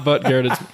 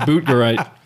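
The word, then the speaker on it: Buttgereit: It reads like Butt Garrett. – This is not Butt Garrett. It's Boot Garrett.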